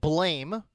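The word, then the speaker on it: Blame!: It's spelled Blame with an exclamation point at the end. Blame with an exclamation point. It's pronounced Blam, I guess.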